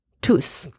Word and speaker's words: tous: I am pronouncing T-O-U-S in that sentence tous because there is no noun after it.